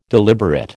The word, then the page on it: deliberate: American English